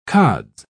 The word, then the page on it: cods: British English